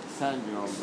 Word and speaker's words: Saint-Jean: My pronunciation of that changes every time. – That's all right. That's, is that all right, Saint-Jean? – Saint-Jean, but yeah, okay.